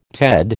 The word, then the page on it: Ted: American English